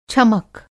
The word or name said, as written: छमक